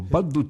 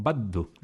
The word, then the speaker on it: baddu: O baddu tundu, que dicen los estos, porque dicen, en vez de ballu dicen baddu, dicen los corsos, parecido a los sicilianos.